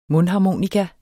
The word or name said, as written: mundharmonika